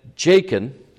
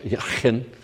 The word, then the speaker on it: Jachin: He called the name of the one on the right hand Jachin, or if we were to pronounce it in Hebrew, pretty much Yachin, anyhow.